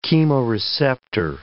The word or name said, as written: chemoreceptor